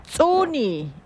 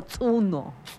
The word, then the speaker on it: tsuni: En Yocotán, colibrí se dice tsuni o tsuno. – Tsuno.